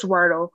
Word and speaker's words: Wordle: Wordly or is it Wordle? – Whatever. – I'm gonna say. – No, I think it is. Of course, me always mispronouncing stuff. It is Wordle, but okay.